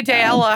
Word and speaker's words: Daella: You know, none of those, but you can say Daella how you want. That's how I say it. It's just, it rhymes with paella.